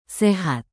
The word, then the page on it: sehat: Indonesian